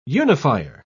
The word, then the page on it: unifier: British English